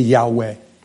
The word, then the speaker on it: Yahweh: And then it's followed by those four tetragram letters, Y-H-W-H, where you would pronounce it Yah. Forgive me, Yahweh.